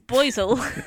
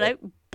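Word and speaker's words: Boisil: A decade later, a plague struck Melrose, and when the prior, who was called Boisel, I checked that as well in case it was a typo. It's Boisel. – You're like, that's not right. Let me just Google that real fast. – Yeah, it's like, wow. No, Boisel. Boisel. I'm going to send you the spelling of it so you can see why I've pronounced it that way.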